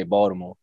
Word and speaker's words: Baltimore: But we don't pronounce the T in Baltimore. We say Baltimore. – Oh, mother. – With a D.